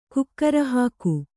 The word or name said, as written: ಕುಕ್ಕರಹಾಕು